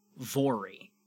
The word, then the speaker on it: Voirrey: The Irving family consisted of James and Margaret, it's the couple, and their 13-year-old daughter named, and I looked up how to pronounce this, Vory. V-O-I-R-R-E-Y.